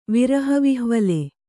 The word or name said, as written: ವಿರಹವಿಹ್ವಲೆ